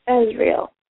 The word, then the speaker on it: Azrael: I thought is it Azrael or Azrael? How do you say that one? – Azrael.